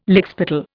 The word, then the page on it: lickspittle: Indian